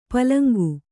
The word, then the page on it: ಪುಲ್ಲಿಂಗ: pulliŋga